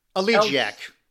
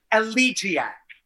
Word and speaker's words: elegiac: And then pretty much I just went to sort of, how is it pronounced? Elegiac? – Elegiac. – Elegiac.